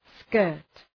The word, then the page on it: skirt: skɜ:rt